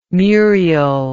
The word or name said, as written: Muriel